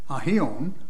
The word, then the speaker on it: aion: In fact, the word here is the Greek word aion, and Strong says you should pronounce it aion, according to him.